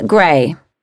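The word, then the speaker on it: Grey: Olivia Gray. – Is that Gray with an E or an A? – With an E.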